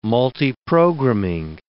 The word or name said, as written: multiprogramming